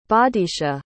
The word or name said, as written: bodicia